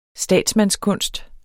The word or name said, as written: statsmandskunst